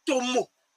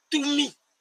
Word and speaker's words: Atum: Je citerai une divinité pour aller vite, qui s'appelle Atum. Que certains prononcent Tomo. Que d'autres prononcent Tumi. Que d'autres prononcent Tem.